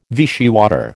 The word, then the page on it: Vichy water: American English